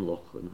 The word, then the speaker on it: Lachlan: I spoke about Lachlan earlier, and that's a good Scottish name, Lachlan. I've been teaching him how to pronounce it properly with a Loch.